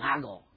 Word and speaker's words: Nago: Pastor Dameron, he didn't know how to pronounce Japanese, so don't listen to him, but it's called Nago.